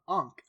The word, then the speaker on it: ankh: an ankh. I think I'm pronouncing it right. – Yeah, that is correct.